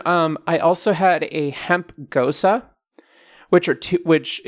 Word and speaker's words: Gose: Can you spell that for our listeners who want to Google it? – Yeah. G-O-S-E. – Gosa. – Gosa. So a gosa is like a lighter alcohol beer.